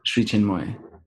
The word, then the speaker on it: Sri Chinmoy: Sri Chinmoy.